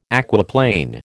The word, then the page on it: aquaplane: American English